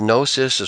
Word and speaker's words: Gnosis: Is Gnosis, it starts with a silent G, G-N-O-S-I-S Comics, is that your company? – Yes.